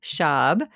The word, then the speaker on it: Schab: It's Schab. It rhymes with Bob. – Oh, okay. So Lisa M. Schab, LCSW, yeah.